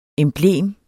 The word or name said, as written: emblem